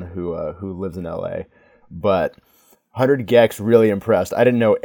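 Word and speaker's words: 100 gecs: I was saying 100 Jex at the show, but that's obviously wrong. – Oh, who knows? – But 100 Gex, they were the first opener.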